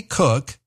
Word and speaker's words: Koch: Dr. Kathy Cook has recipes for better relationships with your adult kids. Her last name is spelled K-O-C-H. Pronounced cook, that's why I call it a cookbook.